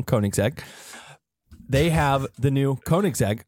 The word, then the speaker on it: Koenigsegg: You taught me how to say this correctly. Koenigsegg.